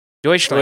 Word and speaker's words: Deutschland: Deutschland. – Deutschland.